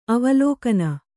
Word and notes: ಅವಲೋಕಿನಿ: kannada

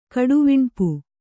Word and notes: ಕಡುವಿಣ್ಪು: kannada

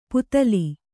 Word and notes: ಪುತಲಿ: putali

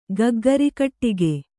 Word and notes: ಗಗ್ಗರಿಕಟ್ಟಿಗೆ: Kannada

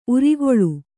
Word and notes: ಊರೆಗೋಲು: ūregōlu

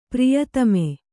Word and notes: ಪ್ರಿಯತಮೆ: Kannada; priyatame

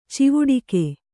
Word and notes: ಚವಡಿಕಿ: cavaḍiki

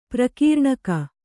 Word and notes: ಪ್ರಕೀರ್ಣಕ: prakīrṇaka

